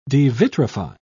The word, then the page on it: devitrify: British English